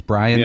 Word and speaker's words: Bryon: By the way, Brian Russell spelling his name B-R-Y-O-N probably caused him not to get that call because it was very frustrating to say his name because it looks like Byron and it's Brian.